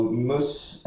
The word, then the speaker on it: MUS: Eh, skribent på MUS hemsida, är det så man uttalar det va? – Ja, men det, det stämmer.